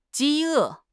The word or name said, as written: 饥饿